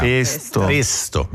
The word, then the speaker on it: pesto: Si pronuncia con la E chiusa in italiano. – Pesto. – Noi qua diciamo pesto però, sì.